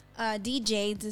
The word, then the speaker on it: De Jadez: No, really, I've heard a lot of people say my name different ways. So DJs is how I originally like started. DJs. So a lot of people know me as DJs.